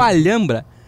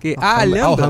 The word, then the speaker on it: Alhambra: Mas o Bruno gosta, então vou poder falar, que é o Alhambra. Alhambra...